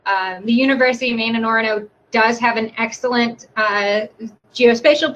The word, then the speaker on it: Orono: Yeah, Orono.